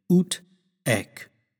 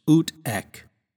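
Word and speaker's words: ut haec: Well, in Latin, we would actually say as this, and that's translated ut ec. Now, even though ec has an H in front of it, we don't have to pronounce that H sound heavily. So it's just ut ec.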